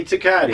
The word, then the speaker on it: Tecate: It's Tecate right here. I know how to say it now. It rhymes with a good name.